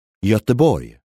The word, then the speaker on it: Göteborg: Göteborg.